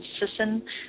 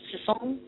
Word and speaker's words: Sisson: I call my last name Sisson. I like it when they French say it because it's Sisson. But it's S-I-S-S-O-N, yes.